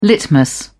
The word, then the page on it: litmus: British English